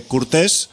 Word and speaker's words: Courtes: que el tipo se llama Alexandre Courtes, o sea, no sé si con acento español al final, pero en francés sería Court, pero Alexandre Court, pero se escribe Courtes, por si lo queréis buscar por ahí.